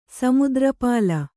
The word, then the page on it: ಸಮುದ್ರಪಾಲ: kannada